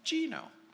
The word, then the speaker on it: Gino: You take the hard G and then you add the I-N-O, Gino.